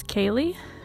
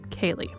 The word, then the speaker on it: Kaylee: My name is Kaylee. I know it looks like it's spelled like Callie, but it's not. It's Kaylee. Today's podcast will be covering a review of Big Magic by Elizabeth Gilbert.